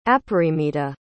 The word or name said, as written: aparimita